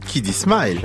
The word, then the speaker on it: Kiddy Smile: Kiddy Smile. – Ah ouais, ça se prononce comme ça, vous l'avez dit.